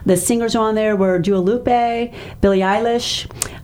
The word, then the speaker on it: Dua Lipa: We are a radio station. It is Dua Lipa.